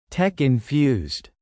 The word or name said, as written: tech-infused